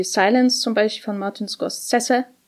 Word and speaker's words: Scorsese: Nein, das ist ein, ein offizieller Scorsese-Experte hat mir mal gesagt, der wird Scorsese ausgesprochen und ist wahrscheinlich der Einzige, der das tut. Hm. Naja. Äh, Scorsese, genau.